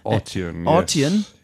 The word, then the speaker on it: Arteon: Arteon. Yes. – And the name Arteon, if I can, the emphasis is put on the first syllable, is derived from two...